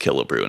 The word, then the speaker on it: Killebrew: I thought it was Killebrew. – I know that there was a baseball player for the Minnesota Twins way back named Harmon Killebrew, and that was pronounced Killebrew and spelled the same, so...